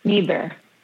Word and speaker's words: Niebur: Niebur.